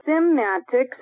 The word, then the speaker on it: Cymatics: There is a book called Symatics. It's spelled Cymatics by Hans Jenny.